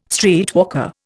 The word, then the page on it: streetwalker: Indian